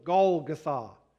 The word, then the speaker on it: Golgotha: The emphasis, the accent being on the last syllable, Golgotha.